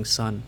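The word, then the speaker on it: Sunn O))): So if you want to look up Sun, they've got a weird way of spelling Sun. It's pronounced Sun, but it's spelled Sun like the amplifier company Sun. So it's like S-U-N-N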